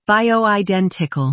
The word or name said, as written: bioidentical